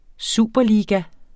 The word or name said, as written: superliga